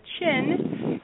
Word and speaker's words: qin: She is also a chin musician, and for our listeners that haven't heard of the chin, it is actually pronounced like your chin, Q-I-N.